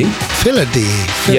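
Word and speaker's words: phyllody: So I think it's Philody. Philody. – Philody.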